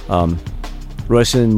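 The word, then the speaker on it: Róisín: Roisin Murphy, that's the proper pronunciation of her name.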